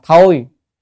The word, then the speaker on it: tồi: Chữ này á, chữ tồi này cũng có nghĩa đọc có âm khác là thôi, nhưng mà trong miền Nam hay đọc là tồi.